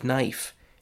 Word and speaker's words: knife: The K at the beginning of knife, the A in the middle of read, or the E at the end of safe.